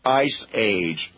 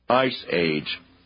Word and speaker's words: ISEAGE: That's ISEAGE, pronounced Ice Age, listeners, Ice Age.